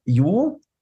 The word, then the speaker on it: Yu: Äh, also wir, wir heißen ja Yu, Y-U, also ich heiße ja Dr. Yu, Jong-un, Held.